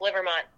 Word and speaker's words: Livermont: No, you got it perfectly. It's pretty phonetic, Livermont.